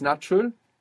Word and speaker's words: natural: So you pronounce a T. A is like A, U is like U, natural. Very good. And the accent on the end as well. It's natural.